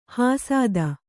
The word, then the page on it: ಹಸಿತ: hasita